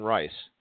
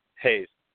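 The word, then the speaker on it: Reis: So in that flyweight fight, is it Wilson Reese or Wilson Rice? – Hayes. He's Brazilian, so the R is silent. It's turned to an H. That's how that mostly works with the Brazilian fighters.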